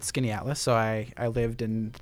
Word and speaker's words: Skaneateles: Skaneateles. So it's spelled like Skaneateles, but Skaneateles. It takes a while for some people to get it,